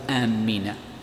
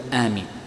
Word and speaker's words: آمين: قالوا يمنع تشديد الميم فيها، فلا تقول آمين وإنما تقول آمين، هذا ما يتعلق بالميم، فلا تشدد الميم فيها وإنما تسهلها بكسرها.